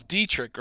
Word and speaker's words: Dietterich: Um, it is Dietterich, right? You don't you don't pronounce it any other special way, do you, Troy? – Yeah, you actually pronounce it exactly right.